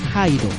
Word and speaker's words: Hyde: de H-I-D-E a H-Y-D-E. Y ya no se pronuncia Hide, se pronuncia Haido.